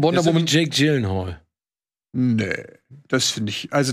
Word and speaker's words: Gyllenhaal: Jake, außerdem spricht man das Gyllenhaal. Gyllenhaal. Gyllenhaal.